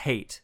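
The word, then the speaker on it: Hayt: We know for sure that his name is pronounced Hate because Alia says the word Hate, H-A-T-E, and Paul's and Hate is like, huh? Like, what? Who's talking to me? – Oh, really?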